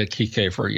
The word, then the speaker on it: Kiekhofer: And if I'm not mistaken, maybe you can tell me how to pronounce this guy's name. Carl Kiekhofer? Kiekhofer? – Yeah, Kiekhofer, yeah. Kiekhofer, it's a little hard to say.